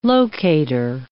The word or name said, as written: locator